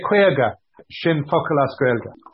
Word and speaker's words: Kwayga: Now, we've spelt it phonetically with K-W-A-Y-G-A to help our international non-Kwayga friends to, right. to say the word Kwayga.